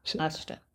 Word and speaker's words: hasta: So I was about to make a correlation between sera, which is Italian, and hasta, which is... – Hasta. – Hasta. – H is silent.